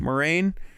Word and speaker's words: Moraine: Anyways, so Morgaine, am I pronouncing Moraine? – That? Moraine.